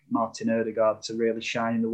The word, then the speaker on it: Odegaard: Odegaard, that's the way you pronounce it, got when he sort of drifted in between the lines.